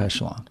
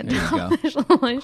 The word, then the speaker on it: echelon: But Ari's, you know, top of the, how do you say that word? Echelon? – Yeah, top echelon. – Yeah, echelon.